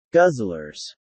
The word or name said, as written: guzzlers